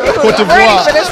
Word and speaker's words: Côte d'Ivoire: He said pronounced it right. Hard. He said it right. – Say that shit again, my nigga. – Côte d'Ivoire.